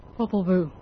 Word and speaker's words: Popol Vuh: Popol Vuh.